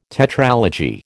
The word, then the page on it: tetralogy: American English